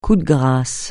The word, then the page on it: coup de grâce: francais